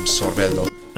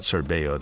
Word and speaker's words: Sorbello: And since he's Italian, I think you would pronounce him Sorbello. – I think so too, yeah, not Sorbello, that would be Spanish.